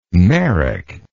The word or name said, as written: Merrick